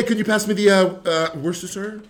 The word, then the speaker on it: Worcestershire: It's like saying Worcestershire. Like no one said, so you might as well just say it really fast. Worcestershire.